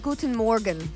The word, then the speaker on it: Guten Morgen: Good morgen. Is that how you say it? Guten Morgen. – Guten Morgen. – Guten Morgen. That's German for good morning.